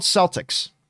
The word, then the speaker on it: Celtx: It was called Celtics, and it's spelled Celtx.